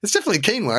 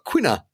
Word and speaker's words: quinoa: It's not quina salad, is it? – No, I mean, at one stage it was quinoa when nobody knew what it was. And then we all learned how to pronounce it. Quinoa. – So it's definitely quinoa.